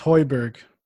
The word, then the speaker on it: Højbjerg: It's Peter Hojbjerg. – It's Højbjerg. – Højbjerg. – That's how you pronounce it.